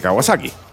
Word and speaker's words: Kawasaki: que era la W1 de Kawasaki.